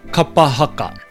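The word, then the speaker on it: kapa haka: In our pause for pronunciation today, we're going to look at the word kapahaka. And I've picked this word because it is quite a simple word in that it's only got one vowel, that being A, yet it is still a word that I quite often hear butchered.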